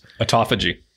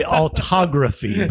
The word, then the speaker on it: autophagy: Autophagy. – I want to say autography, but it's autophagy.